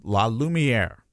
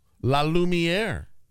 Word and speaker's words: La Lumiere: By the way, thanks to one of our maniacs who sent us a very nice note on Twitter and said it's pronounced La Lumiere. – Oh, La Lumiere. – As though it's French.